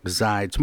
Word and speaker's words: Zides: Mark Zides.